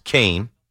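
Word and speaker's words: Kean: It's spelled Keen like bean with a K, but it's pronounced Kane.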